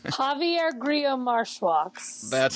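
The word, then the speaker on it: Javier Grillo-Marxuach: I never know how to pronounce his name. – Javier Grillo Marshwax. – That's it.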